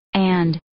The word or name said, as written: and